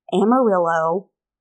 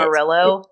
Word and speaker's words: Amarillo: It's about, the way I pronounced freaking Amarillo. I get Amarillo. – It. Amarillo? Amarillo.